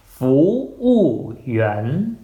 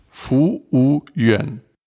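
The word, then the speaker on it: Fu Wu Yuan (服务员): Nee, alles hast du richtig ausgesprochen, aber trotzdem können wir nochmal wiederholen, und zwar langsam. Fu Wu Yuan, das ist Kellner, Fu Wu Yuan. – Fu Wu Yuan.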